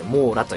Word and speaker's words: MOURA: 講談社モーラはですね MOURA と書いてモーラと読みます。